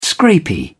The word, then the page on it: scrapie: British English